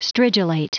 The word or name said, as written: stridulate